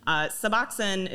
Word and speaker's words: Suboxone: So turning corners a little bit, I've been hearing about this drug Suboxone. Am I saying that correctly? – Suboxone? Yes, okay. Suboxone.